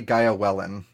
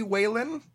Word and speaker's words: Weylyn: I usually will say GaiaWeylyn, but since it's Celtic, it's supposed to be Weylyn. Either one is perfectly fine, but I go by Gaia, so.